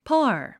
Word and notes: par: pάː r; パァー

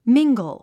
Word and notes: mingle: míŋɡl; ミンゴゥ

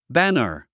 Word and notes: banner: bǽnər; バナァ

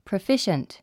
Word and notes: proficient: prəfíʃənt; プロゥフィッシェエン

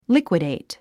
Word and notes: liquidate: líkwidèit; リクイディト

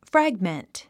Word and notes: fragment: frǽɡmənt; フラグメント

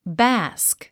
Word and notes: bask: bάːsk; バスク

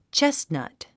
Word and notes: chestnut: tʃésnʌ̀t; チェスナト

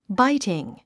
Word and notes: biting: báitiŋ; バイティン

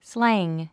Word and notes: slang: slǽŋ; スラァング

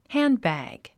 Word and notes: handbag: hǽn(d)bæ̀g; ハンドバッグ

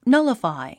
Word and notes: nullify: nʌ’ləfài; ナラファイ

